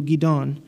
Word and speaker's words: Gidon: Gadon is a reference to the biblical warrior Gideon. It's pronounced in Hebrew Gidon.